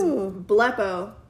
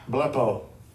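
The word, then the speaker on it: blepo (βλέπω): The way you pronounce it is blepo. – No, that is probably, you can say it so they can hear it. – Psalms G 991, blepo.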